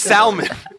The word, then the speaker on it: salmon: And it's like, or how I used to say it, salmon. I used to pronounce the L. It's salmon.